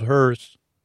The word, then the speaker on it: Hearth: So they had a backup Earth called Hearth with a silent H, but I say it now just so you know it's called Hearth.